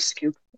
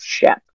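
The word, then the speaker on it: Schoep: Well, Jeff Scoop is how you pronounce it, but everybody that I know as a deliberate act of disrespect in the former community calls him Jeff Schupp.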